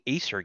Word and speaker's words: acer: Oh, I think that's also where the company Acer gets their name from now that I think about it. Anyway, but it's Latin, so the C would be a hard C.